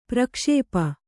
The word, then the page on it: ಪ್ರಕ್ಷೇಪ: prakṣēpa